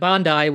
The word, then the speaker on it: Bondi: but Bondi, which is the apparently proper pronunciation of Bondi, which is a beach in Australia, which is whatever Australia.